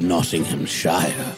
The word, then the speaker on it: Nottinghamshire: I will sing the achievements of General Ludd, now the hero of Nottinghamshire. – See, now this tells us how you pronounce it. This is the rhyme. – Shire and Shire.